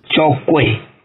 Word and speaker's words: Tchokwe: Pois é, no bloco anterior, você cita aí epistemologias quilombolas e também Tchoko. A pronúncia está correta? – Tchokwe.